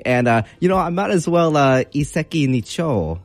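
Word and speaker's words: Iseki Nicho (一石二鳥): So again, it's going to be Iseki Nicho. So it's going to be pronounced in two different words here. So the first one is Iseki, Iseki, and the second part is Nicho, Nicho.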